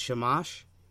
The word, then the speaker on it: Shamash: Things like the Assyrian exorcisms, they actually involved magical rituals, incantations, invocations of deities, like the god Shamash.